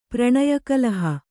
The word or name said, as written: ಪ್ರಣಯಕಲಹ